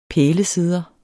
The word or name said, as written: pælesidder